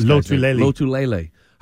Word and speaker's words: Lotulelei: Lotulelei.